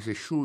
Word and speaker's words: sure: Sure, S-U-R-E, ça veut dire certainement. En effet, sure, décrit sûr, mais c'est sure, vous prononcez en anglais. Sure, S-U-R-E.